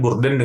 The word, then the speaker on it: BURDN: Uh, pokoknya ini silahkan didengarkan Burden dengan Jessica. Bacanya gitu ya. Soalnya tulisannya B U R D N Susah. Ini bacanya gue Burden aja udah udah.